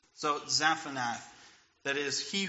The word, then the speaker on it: Zaphnath: So Zaphnath, right? It is likely this Egyptian phrase, which is pronounced roughly Zatanath.